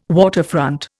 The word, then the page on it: waterfront: Indian